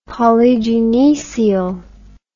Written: polygynoecial

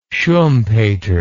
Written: schumpeter

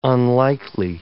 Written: unlikely

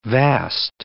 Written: vast

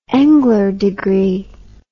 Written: Engler degree

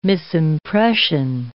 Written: misimpression

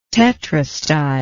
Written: tetrastyle

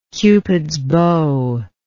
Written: cupid's bow

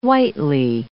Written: whitely

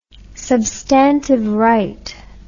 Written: substantive right

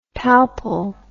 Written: palpal

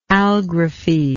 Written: algraphy

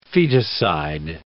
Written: feticide

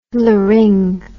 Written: laryng

